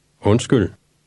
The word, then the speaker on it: Undskyld: Undskyld.